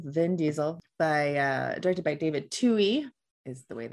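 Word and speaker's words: David Twohy: I thought it was Twoe, just by the way that it was spelled, but it's Tui.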